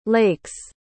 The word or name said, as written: lake's